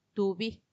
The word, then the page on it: tubi: Macuiltianguis